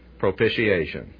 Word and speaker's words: propitiation: You often hear it propitiation. The T is CH.